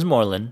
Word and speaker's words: Morlun: It's Morlun.